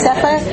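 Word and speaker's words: Safire: And William Sapphire, as you know, am I pronouncing the name correctly? – Yes. – Sapphire? – Yes.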